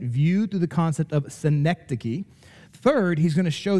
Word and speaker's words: synecdoche: Okay, I'm going to spell that for you. I have trouble even pronouncing it, but I'm going to spell it for you, and then I'm going to try to pronounce it multiple times as we go through the sermon. So synecdoche, S-Y-N-E-C-D-O-C-H-E, synecdoche. Okay?